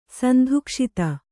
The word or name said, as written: ಸಂಧುಕ್ಷಿತ